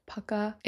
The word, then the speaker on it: Pukka: I personally love the brand Puka. Okay, I thought it was called Puka, but I think it's pronounced Pukka. It's P-U-K-K-A.